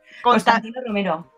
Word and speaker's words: Constantino Romero: Tía, pronúncialo tú. Ya no puedo. – Constantino Romero. Eso.